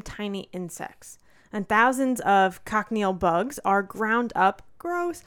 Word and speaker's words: cochineal: So it's a dye called carmine or cochineal.